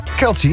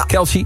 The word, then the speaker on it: Kelce: Kelce, ja. – Kelce.